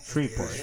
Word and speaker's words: Shreveport: Shreveport.